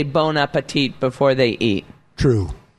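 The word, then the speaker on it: bon appétit: Well, they don't say bon appetit because we don't pronounce the T at the end of bon appetit.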